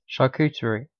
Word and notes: charcuterie: British English

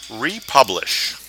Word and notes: republish: American English

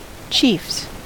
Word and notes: chiefs: American English

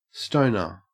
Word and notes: stoner: Australian English

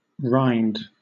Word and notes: rind: British English

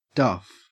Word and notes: duff: Australian English

